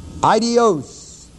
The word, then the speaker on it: aidios: The word eternal is the Greek word Aidios, A-I-D-I-O-S. The word power is the word dunamis, D-U-N-A-M-I-S.